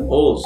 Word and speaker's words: Ulz: Ulz.